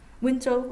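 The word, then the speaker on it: Wenzhou: Alors vous vous êtes surtout concentré sur l'étude de personnes originaires de Wenchu, on prononce comme ça? – Wenchu, oui.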